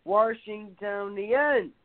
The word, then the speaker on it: Washingtonians: Residents are called Washingtonians, emphasis on the third syllable pronounced as tone.